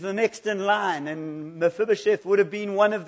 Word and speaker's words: Mephibosheth: In the Old Testament, there's this wonderful story about Mephibosheth.